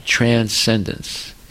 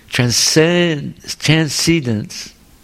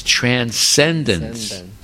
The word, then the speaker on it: transcendence: See, in English, the emphasis is on the first syllable, transcendence. If you say transcendence, it's a mispronunciation. Nobody understands what you're saying. No one will understand what you're saying. If you say transcendence, no one will understand. It's transcendence.